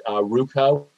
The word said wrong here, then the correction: Rucho: it's RUCHO, versus Common Cause.